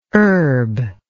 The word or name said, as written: urb